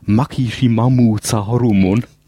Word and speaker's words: Maximum the Hormone: Ausgesprochen im Japanischen übrigens Makishimamu the Hormone. Aber geschrieben Maximum the Hormone.